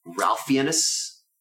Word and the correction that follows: Ralph Fiennes: Ray Fiennes. – Ray Fiennes. – Don't pronounce the L. It's real weird.